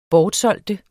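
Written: BORTSÆLGES

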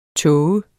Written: tåget

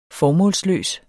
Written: formålsløs

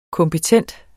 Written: kompetent

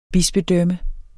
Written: bispedømme

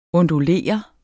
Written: ondulere

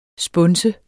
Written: spunse

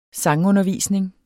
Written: sangundervisning

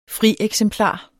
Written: frieksemplar

